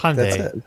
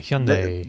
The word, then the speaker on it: Hyundai: How do you say Monday or Sunday? – Sunday. – Yeah, there you go. You just put an H in front of it. – Hyundai.